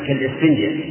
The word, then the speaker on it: إسفنجة: هذه الوصية لا تجعل قلبك كالسفنجة أنا أحكي هذا فيها همزة كالإسفنجة هكذا ننطق به.